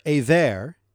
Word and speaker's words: Eber: Also to Shem, the father of all the children of Aver, and the older brother of Japheth, children were born. That name there, Aver, in English is spelled E-B-E-R, and it is thought by some to be the origin of the word Hebrew.